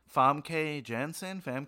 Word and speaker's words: Famke Janssen: I always say this person's name wrong, but Famke Janssen? – Yeah, close enough.